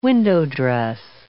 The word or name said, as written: window-dress